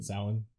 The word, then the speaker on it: Samhain: You know, it's spelled like Samhain, but Samhain.